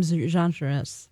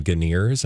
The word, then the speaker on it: genres: If for its genre, let's see, what are some genres? – I had a friend who used to call them Ganeers, and I was like, Ganeers. – I don't think that's how you pronounce that word.